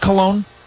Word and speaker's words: Cologne: After all, is that, am I pronouncing that right? Do you pronounce it Cologne, yes.